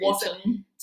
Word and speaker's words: Wotton: Wotton. – So Cotton with a W. Wotton.